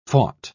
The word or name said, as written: fought